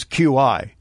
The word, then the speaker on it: Qi: And that, by the way, is QI. It's pronounced Qi because it's Chinese.